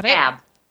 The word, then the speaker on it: Kanab: Is that Kanab.